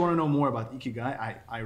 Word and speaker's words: Ikigai: Ikigai, is that how you pronounce it? – Yeah, it is.